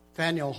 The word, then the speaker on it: Faneuil: But then I was with Jamie and John Hamill and the team in Faneuil Hall in Boston, and that's actually the right pronunciation of it.